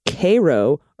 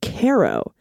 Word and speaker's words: Cairo: In the same county called Cairo, okay, and no one except out-of-towners pronounces it like the Egyptian city. Oh, it's either pronounced Cairo or Caro, despite the fact that now closed high school was the Pharaohs.